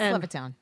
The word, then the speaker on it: Levittown: He owns Governor's in Levittstown, Long Island. I'm probably saying it wrong because it's probably Levittsville. – No, it's Levittown. – Oh, is it Levittown?